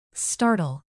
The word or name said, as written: startle